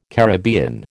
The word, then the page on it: Caribbean: American English